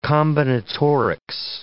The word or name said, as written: combinatorics